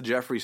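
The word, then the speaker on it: Geoffrey: That's the Jeffrey spelled with the G-E-O-F-F.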